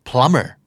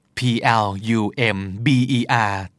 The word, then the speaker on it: plumber: plumber p l u m b e r แต่ออกเสียงเหมือนไม่มี b เลยนะครับ